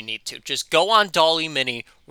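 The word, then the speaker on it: DALL·E Mini: Anyway, the second thing I have is this little website called Doll-E Mini. Spelled like Wally, but with a D for the W.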